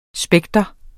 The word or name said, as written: spektrum